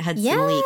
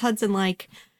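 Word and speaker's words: Hudson Leick: In my head, I'd always pronounced it Hudson Leak. But I'm pretty positive it's Hudson Like.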